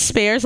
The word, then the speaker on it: Spears: My last name is Spears, like Britney. The Britney is silent.